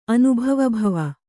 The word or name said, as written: ಅನುಭವಭವ